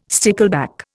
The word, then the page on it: stickleback: Indian